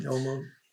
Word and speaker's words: Olmos: Edward James Olmos, is that how you pronounce him? – Olmos, yep.